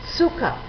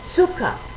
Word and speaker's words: Zucker: En sexto lugar, la Z la pronunciaremos siempre como TS, Zucker, Zucker, azúcar.